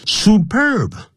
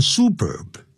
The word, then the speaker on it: superb: superb 不要念成 superb， superb songs 就是棒透的。